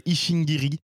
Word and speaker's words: Ishingiri: C'est un groupe, ou en tout cas un projet musical qui s'appelle Ishingiri. Donc, comme Ish comme Isham, H-I-C-H et Ingiri, comme ça se prononce.